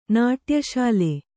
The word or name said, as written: ನಾಟ್ಯಶಾಲೆ